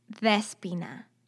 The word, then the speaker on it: Despoina: But in Greek, O and I together are pronounced E. So it's Despeña.